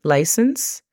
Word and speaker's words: license: La pronunciación fonética de esta sería igual que hace un momento que mencioné licencia, L-A-I-S-E-N-S, e license, y número, number, N-A-M-B-R.